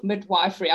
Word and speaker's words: midwifery: She is also Professor of Nursing in the School of Nursery and Midwifery, Griffith University. Did I say that right? Is it midwifery or midwifery? I always look at this tongue twister. – Yeah, midwifery. – Midwifery.